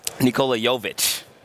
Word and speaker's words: Nikola Jovic: But I really like the Serbian kid, Nikola Jovic.